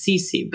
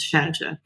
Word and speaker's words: Cece: I get it because in high school, you probably read my last name and you're like, in English it's Cece, but in Italian it's Cece.